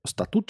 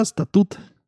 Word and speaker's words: статут: Ну, поскольку наш статут. Статут читается, да? Ой, даже так просто не узнаешь, на какое ударение ставится. Да, статут. Понятно. Никогда не знал. Всегда говорил статут.